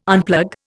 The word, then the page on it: unplug: Indian